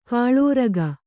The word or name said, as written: ಖಲೂರಿಕೆ